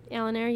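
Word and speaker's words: Oalinare: Alinar, yeah.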